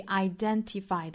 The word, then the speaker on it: identify: You've got to identify，注意 identify 的重读音节在 den 这个音节上。